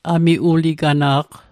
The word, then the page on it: amiuligana'q: Mi'gmaq